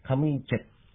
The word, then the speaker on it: カムイチェプ: カムイチェプって、チェの後にちっちゃいイプが入ります。ちょっと発音が難しい言葉です。